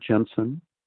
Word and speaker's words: Jensen: Well, if people put my name, Robert Jensen, uh, into Google, and if you spell it right, it's J E N S E N.